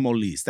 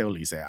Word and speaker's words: Olise: And the French call him Olise. That's how you pronounce his name. But we say Olise.